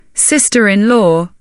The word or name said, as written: sister-in-law